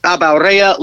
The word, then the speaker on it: Abaurrea: Uh, on Twitter at Nate Abaurrea, last name A B A U R R.